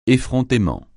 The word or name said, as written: effrontément